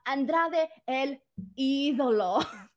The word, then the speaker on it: Andrade El Idolo: FYI, for those of you guys who are confused on the pronunciation, uh, Andrade El Idolo. It's not that hard.